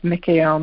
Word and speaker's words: Mikael: So the guidance I received was to use these Hebrew names for God and to also to chant Michael's name under the pronunciation of Mikael, Mikael, Mikael. There's many different pronunciations. Michael is just how it's said in English.